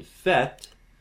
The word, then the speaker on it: fête: Now, "fête" is uh, F E circumflex T E, and "sait" he knows the T is silent. In fet, the T mm-hmm. is not silent. And so the E is longer.